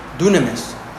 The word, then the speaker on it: dunamis: Now this word power, it's spelled out dynamis, but it's spelled out, but it's pronounced dunamis.